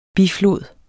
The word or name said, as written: biflod